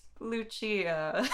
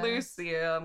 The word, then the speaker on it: Lucia: It's pronounced Lucia from Devil May Cry 2, not Lucia.